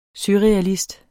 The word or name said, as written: surrealist